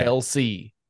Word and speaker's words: Kelce: And I don't know how to say his name. – Kelce. – Travis Kelce. Kelce. The C comes before the E, though. – No, it doesn't. It comes after. It's K-E-L-C-E.